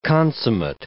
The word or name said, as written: consummate